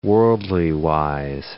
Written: worldly-wise

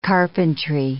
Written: carpentry

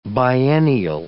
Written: biennial

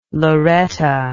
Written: Loretta

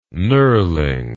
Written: knurling

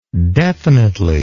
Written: definitely

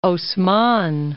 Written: Haussmann

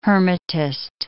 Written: hermetist

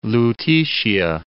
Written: Lutetia

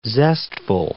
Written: zestful

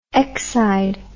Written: exide

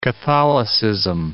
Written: Catholicism

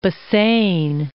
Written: Bassein

